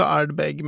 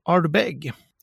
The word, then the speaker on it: Ardbeg: Eh, Ardbeg, vi kan börja med hur det uttalas, för jag säger också Ardbeg, men är man i, i Skottland och, och pratar man engelska, då heter det faktiskt Ardbeg. – Ardbeg. Med betoning på nummer två. Och är man riktig skotteskotte då är det ju typ så här Ardbag.